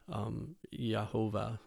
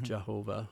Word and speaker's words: Yehovah: In the believers of the Old Testament, the Hebrew people, so honored God's name that they wouldn't even pronounce Yod Heh Vav Heh, which we pronounce Yehovah or mm-hmm. Jehovah.